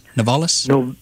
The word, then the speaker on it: Novelis: First, the thing at Austell, and second, the thing with Novartis over in Did I pronounce that right? Novalis? – Novelis. – Novelis.